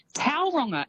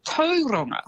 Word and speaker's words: Tauranga: And Tauranga as well is actually Tauranga.